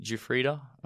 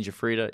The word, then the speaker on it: Giuffrida: I'm on JP Giuffrida. So JP is my first two initials, and then Giuffrida is my last name. But how do you spell that? – it's a tough one, but it's Giuffrida.